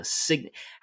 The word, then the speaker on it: signet: It's like his signet. How do you say that word? Is it signet? – Signet. Yeah.